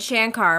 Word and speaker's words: Shankar: And Carrie said it wrong. – Well, she said Shankar. – So here's a little side thing. I said Shankar my whole life. And then only in the last like couple years have I've always known it's wrong,